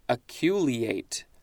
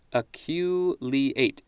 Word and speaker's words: aculeate: Next is, let's see if I can pronounce this, Aculeate. Aculeate.